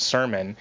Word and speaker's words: SERMN: So basically, the acronym itself, sermon, which is mm-hmm. uh, S E R M N, pronounced sermon, uh, it stands for Swap Escape Routes Metro New York.